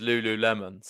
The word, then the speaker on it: Lululemon: You are right as well with the pronunciation. They're confirming it is Lululemon.